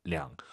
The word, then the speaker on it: 两: 우리도 이명이라고 안 하잖아요. 두명 그러죠. 얼이 아니라 량.